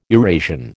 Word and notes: Eurasian: American English

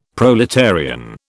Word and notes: proletarian: British English

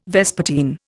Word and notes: vespertine: Indian

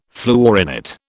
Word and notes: fluorinate: British English

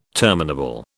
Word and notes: terminable: British English

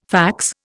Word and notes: fax: Indian

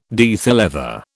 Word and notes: diethyl ether: British English

